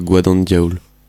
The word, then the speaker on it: Guad An Diol: Ça se prononce plutôt Guad An Diol.